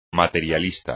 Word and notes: materialista: Spanish

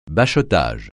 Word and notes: bachotage: francais

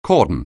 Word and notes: cordon: anglais

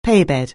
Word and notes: paybed: anglais

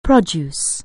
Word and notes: produce: anglais

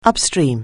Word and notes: upstream: anglais

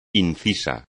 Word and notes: inciso: espagnol

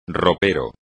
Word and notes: ropero: espagnol

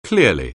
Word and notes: clearly: anglais

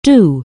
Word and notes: do: anglais

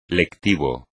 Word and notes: lectivo: espagnol

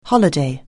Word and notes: holiday: anglais

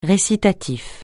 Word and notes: récitatif: francais

